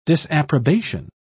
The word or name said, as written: disapprobation